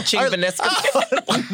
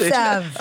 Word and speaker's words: Chigvintsev: I sure can. Ching Vanisca. – No. Our resident linguist. – Yes, Ching Vanisca is how it's pronounced. – I'm pretty sure it ends in a sev. – Okay. – Take a rest. – Chigvintsev. – Oh, it's not that hard.